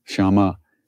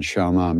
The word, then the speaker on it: Shyama: Gauri, Shyama Gauri, and Shyama, the long A at the end, Shyama, makes it the feminine, and it refers to Kali, the dark goddess, the dark colored goddess.